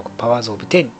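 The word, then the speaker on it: Powers of X: パワーズ・オブ・テン 10 っていうね X ではなく 10 って発音これ読みますよっていうのもね、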